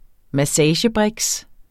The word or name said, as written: massagebriks